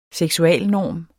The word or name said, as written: seksualnorm